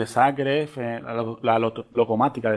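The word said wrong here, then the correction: Lokomotiva: de Zagreb, perdón. – El no, creo que es Locomotiva, ¿no? – No sé cómo es es que no estoy seguro cómo se pronuncia, pero. Lo, estoy mirando. NK Locomotiva de Zagreb. Además, es un equipo antiguo, ¿eh? fundado en el 1914, Locomotiva, sí.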